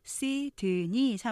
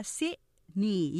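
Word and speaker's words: Sydney: 시드니 삼음절이 아니라 시니 이음절 하는 것처럼 이것도 gold coast 이음절로 하면서 t 발음 거의 안 나요.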